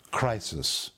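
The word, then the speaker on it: krisis: It is literally pronounced crisis.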